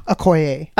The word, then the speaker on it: Okoye: Okoye.